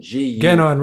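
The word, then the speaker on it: Guénon: Rene Ginot, right? Guy, I never pronounce his name right. I'm Genon, Rene Genon, yeah, his the traditionalist.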